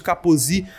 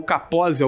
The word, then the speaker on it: Kaposi: A gente também vai observar a presença do sarcoma de Kaposi ou Kaposi, algumas pessoas é, é, pronunciam de maneira diferente, que é uma forma rara de câncer encontrada principalmente em idosos, representado por uma manchinha que dá na pele.